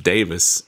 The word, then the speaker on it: Davies: I believe it's pronounced Davis in Great right? – Britain.